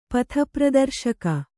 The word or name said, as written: ಪಥಪ್ರದರ್ಶಕ